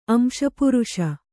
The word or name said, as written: ಅಂಶಪುರುಷ